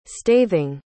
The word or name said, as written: staving